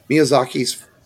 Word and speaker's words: Miyazaki: This was Hayaki... I'm always butcher his name. Miyazaki's follow-up to Spirited Away.